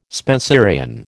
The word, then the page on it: Spenserian: American English